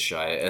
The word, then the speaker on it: Shia: So first I'll say Shia. I don't know. – Is it Shia? – I think it's Shia. S-H-I-A.